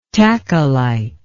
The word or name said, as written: tachylyte